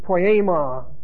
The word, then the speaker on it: poiema: And the word for made is the word poiema. P-O-I-A-E, actually long E, M-A.